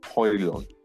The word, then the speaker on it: Højlund: This season, I have faith in Rasmus Højlund. The D is silent.